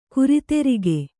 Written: ಗಿರಿದುರ್ಗ

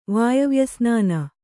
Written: ವಾಯವ್ಯಸ್ನಾನ

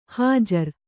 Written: ಹುಜರು

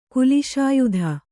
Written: ಕುಲಿಶಾಯುಧ

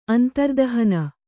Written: ಅಂತರ್ದಹನ

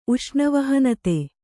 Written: ಉಷ್ಣವಹನತೆ

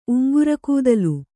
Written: ಉಂಗ್ರಗೂದಲು

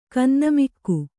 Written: ಖಿನ್ನಮುಖಿ

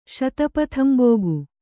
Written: ಶತಪಥಂಬೋಗು

